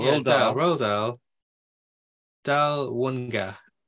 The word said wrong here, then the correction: Dahlonega: Dahlonega. – Crap. Dahlonega. Wow. Dahlonega. It's spelled D-A-H.